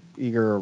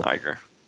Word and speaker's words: Iger: So, Bob Iger or Eager, whatever you say his name, the Disney CEO. – Iger.